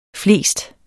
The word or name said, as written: flest